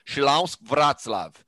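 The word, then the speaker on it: Śląsk Wrocław: Então, ó, Chilausk Vratslav, tá aqui a pronúncia mais próxima aí.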